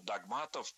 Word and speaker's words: догмат: Посты, конечно, они определялись верой, они религиозные, они от догматов отходят, да, и, соответственно, определяются. – Догматов, догмат. Такое ударение в этом слове стало нормативным совсем недавно. А раньше нужно было говорить догмат, догматов.